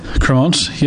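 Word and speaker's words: Crémant: So what's the story with Cremant? Am I saying it correctly? – Cremant, yes, yeah.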